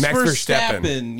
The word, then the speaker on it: Verstappen: It's something like Verstappen or something like that. – Max Verstappen. Yes. – Verstappen, that's what it is. – I will give it to you. That's probably how you pronounce it in Dutch too.